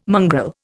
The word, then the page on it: mongrel: Indian